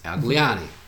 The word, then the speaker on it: Gliane: I usually tell people they have when they have difficulty pronouncing my last name to think Italian, and they get it. – Okay, but that makes sense. Al Gliane.